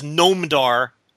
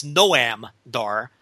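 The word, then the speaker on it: Noam Dar: First of all, I thought it was Gnomedar. We now come to find out it's Noamdar.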